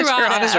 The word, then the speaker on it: Trūata: So tell us a little bit about Trūata, or how do I pronounce it? – Trūata? No, Trūata's right. – Where do we stand? Trūata. – Trūata's right.